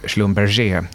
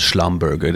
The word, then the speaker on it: Schlumberger: Schlumberger. Det låter så här, det låter så här slemmigt. Liksom. ja, men, eh, ingenting som måste uttalas på franska är, bra.